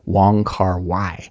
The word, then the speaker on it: Wong Kar-wai: Many of you are probably familiar with him, and I will probably butcher the pronunciation of his name, but in English we say Wong Kar-wai.